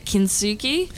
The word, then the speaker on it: kintsugi: So the whole story of Unbroken is the kintsugi. Is that how you say it? – That's how you say it. Kintsugi.